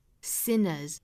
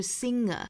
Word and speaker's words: sinners: We're all 后面 sinners. Sin 这个词大家也是要注意，不要读成了后鼻音，不是 singer，不要混淆了那个歌手的那个词，就是 singer singers，这里面是 sinner，舌尖呢是要去抵上齿龈的位置， sinner 后面加的这个复数 s 应该是读浊音的 z， sinners。